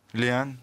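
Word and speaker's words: Lehane: qui est une adaptation d'un roman de Denis Lehan, si je le prononce bien. Lehan merci Ryan.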